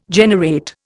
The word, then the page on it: generate: Indian